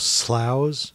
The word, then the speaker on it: Slough: They call themselves Sloughs, S-L-O-U-G-H. – After Slough, where Heathrow Airport is. – Oh, that's how you pronounce that? – It is. Yeah.